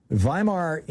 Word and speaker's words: Weimar: Weimar, you know, this is the we say Weimar, but in German it would be pronounced, W's are pronounced like V's.